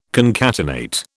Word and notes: concatenate: British English

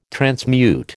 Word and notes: transmute: American English

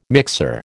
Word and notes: mixer: American English

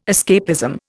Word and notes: escapism: Indian

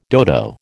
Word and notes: dodo: American English

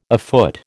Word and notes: afoot: American English

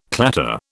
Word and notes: clatter: British English

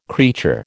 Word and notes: creature: American English

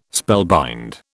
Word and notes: spellbind: British English

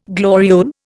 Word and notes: gloriole: Indian

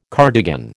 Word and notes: cardigan: American English

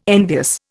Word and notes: envious: Indian

